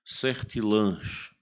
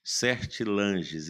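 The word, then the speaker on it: Sertillanges: Esse livro é de Antoine Gilbert Certilange, é um francês. Certilanges escreve assim, mas se pronuncia Certilange.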